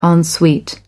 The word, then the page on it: en suite: American English